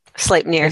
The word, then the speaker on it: Sleipnir: Sleipnir, the eight-legged stallion.